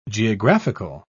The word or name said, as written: geographical